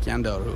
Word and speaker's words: Scandal: Scandal, but it's pronounced Scandaru.